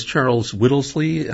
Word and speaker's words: Charles Whittlesey: And this Charles Whittlesey, I'm pronouncing the name right? – Yes, Charles.